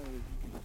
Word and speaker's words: Vecus: Juste au niveau de la prononciation, pour préciser, on dit Vécu, ça s'écrit V-E-C-U-S, mais on prononce ça Vécu, on a pris ce nom parce que ça signifie village.